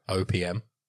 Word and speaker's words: OPM: Is that how you pronounce it? – How do you pronounce it? – OPM. I pronounce each letter individually, whereas you opium.